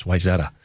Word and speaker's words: Wayzata: families had moved in from Wayzata, Minnesota. Wayzata. I don't know if they ever changed that, but that's how they said it. That's how the actors and actresses said it in 90210, Wayzata. It's Wayzata.